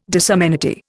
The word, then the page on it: disamenity: Indian